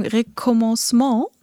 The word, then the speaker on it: Recommencement: Aber ich weiß, ähm, dein Song Recommencement Mhm. Habe ich das jetzt mhm. richtig ausgesprochen? Ja, sehr gut.